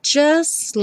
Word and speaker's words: just: Just like. O T do just não é pronunciado, então o S já vai direto para o L.